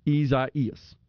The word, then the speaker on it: Isaias: Isaias. The I pronounced as E, which is actually a short I, but it's pronounced E-E-E phonetically.